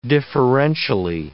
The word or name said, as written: differentially